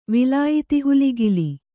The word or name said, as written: ವಿಲಾಯಿತಿಹುಲಿಗಿಲಿ